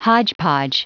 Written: hodgepodge

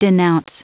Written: denounce

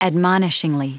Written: admonishingly

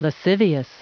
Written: lascivious

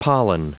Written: pollen

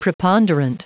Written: preponderant

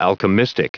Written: alchemistic

